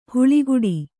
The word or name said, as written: ಹಾಳುಗೋಡೆ